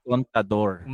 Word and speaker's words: Contador: And that was Mensajero by Manny Contraba. Sorry, Baka mamaya mali pangalan eh. – Uh, Mani Contador.